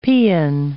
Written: paeon

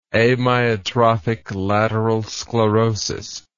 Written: amyotrophic lateral sclerosis

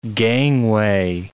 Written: gangway

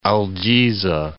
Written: Al Jizah

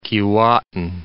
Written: Keewatin

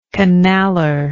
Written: canaller

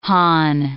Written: Han

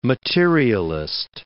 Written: materialist

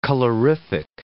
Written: colorific

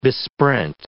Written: besprent

